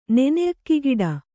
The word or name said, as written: ನೆನೆಅಕ್ಕಿಗಿಡ